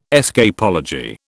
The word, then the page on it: escapology: British English